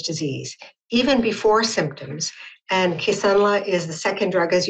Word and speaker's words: Kisunla: Did I say that correctly? Kisunla. – Good morning. Yes, you did. Thank you. Kisunla.